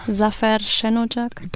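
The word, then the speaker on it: Zafer Şenocak: eben Zava Senocak, den ich wahrscheinlich falsch ausspreche. – Zafer Senocak.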